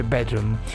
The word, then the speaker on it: BDRMM: In realtà Bedroom non è scritto, è scritto senza le vocali, quindi BDRMM, ma si legge Bedroom.